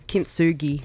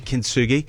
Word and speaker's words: Kintsugi: So how do you pronounce that pottery discipline again? – Kintsugi. – Kintsugi.